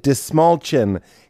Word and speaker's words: Dastmalchian: This is the wonderful actor, amazing human, David Dastmalchian. He taught me how to say his name. It's like you're pointing to your chin and you say, which chin? Dastmalchian. This small chin.